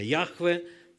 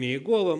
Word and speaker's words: Яхве: На самом деле ЯХЭВЕ, Яхве.